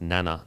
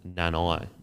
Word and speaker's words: Nana: So I was watching a game a week ago or whatever, and I think Jeremiah has come out and said it is pronounced Nana, not Nanai.